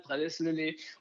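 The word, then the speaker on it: Prades-le-Lez: Et ensuite, alors peut-être que je prononce mal, on dit prades le prades près de Montpellier. – Oh là là, pas mal, prades le lait. On dit prades le prades Prad prades lait.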